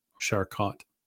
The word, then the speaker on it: Charcot: Jean Martin Carchot, Charcot. Sorry, I probably butchered that for our French listeners.